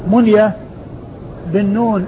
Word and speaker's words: منية: منية بالنون الساكنة والياء المفتوحة, ومنبه بالنون المفتوحة والباء المشددة.